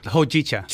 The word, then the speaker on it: Hojicha: Hojicha.